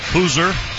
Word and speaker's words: Hauser: Hoosier, spelled Hauser. For our listeners. – Yes, yeah, not pronounced like it's spelled.